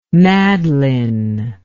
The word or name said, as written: madlyn